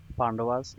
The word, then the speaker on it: Pandavas: He's prince of the Pandavas. Arjuna, am I saying that right? Pandavas, yep.